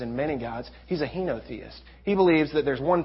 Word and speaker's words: henotheist: He's a Henoist. A Henoist. Is that how you say it? Henoist. What am I trying to? Not a monotheist. He's not a polytheist. He's a henotheist.